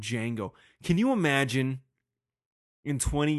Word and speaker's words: Django: I'm sorry, Django. D is silent.